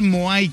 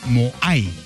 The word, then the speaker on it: Moai: Ok, pues se llama Moai con M, no el no hay, no, es el Moai, ¿ok? Acento en la A, Moai, ok.